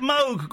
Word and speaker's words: Moog: He pronounces it Moog, Graham.